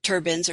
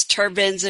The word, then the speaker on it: turbine: To what they would be doing is assembling the wind turbines or turbines. We looked this up before the show. It's turbines. If you're American pronunciation, turbine. If you're the British, I'm going with the British because that's what I'm used to for some reason.